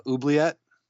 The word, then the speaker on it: Oubliette: He's like, the only removal he drew was Oubliette.